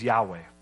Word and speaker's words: Yahweh: It's really the way the Hebrews would say it is Yahweh.